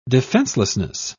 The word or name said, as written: defenselessness